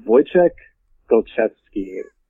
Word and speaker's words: Wojciech Golczewski: It's Wojciech Golczewski, which is, from what he has said, that's a fairly accurate pronunciation of it. He's from Poland.